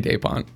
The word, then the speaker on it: DaPonte: In Portuguese? Yeah. DaPonte.